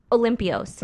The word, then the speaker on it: Olympios: Corinne Olympios. Did I say it right? – Yeah, Olympios. – Oh, Olympios. Okay.